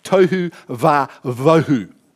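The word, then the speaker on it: tohu wa-bohu: It's tohu vavohu. It's perhaps not spelt like that, but it's actually pronounced tohu vavohu.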